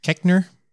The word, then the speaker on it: Koechner: Well, I gotta say, my favorite characters, like David Koechner, I think is how to say it. – Koechner.